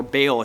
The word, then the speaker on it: Baal: You see, if you don't know anything about the god Baal, or Baal, as sometimes we pronounce it, this was the god of fertility.